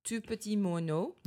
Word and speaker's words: Tout petit moineau: How do you say that? – Tout petit moineau.